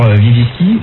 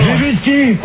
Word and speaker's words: Lewinsky: À la question, que pensez-vous de l'affaire Lewinsky? Donc les Français ont répondu non. – Lewinsky.